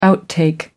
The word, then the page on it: outtake: American English